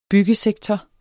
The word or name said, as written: byggesektor